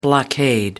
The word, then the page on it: blockade: American English